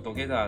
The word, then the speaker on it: 土下座: 嗯，我们从半泽直树的第一季开始，我们就发现到它一直都有一个土下座，念作 dogeta 这样子的一个套路。关于土下座，你可以去理解，它是一个日本特有的以个人的方式，仪式化的呈现一个最高规格的道歉表达方式。从资料上来看，土下座可以分作三种，就是黑色 kuro dogeta，还有白的 shiro dogeta，还有假装的 nisei。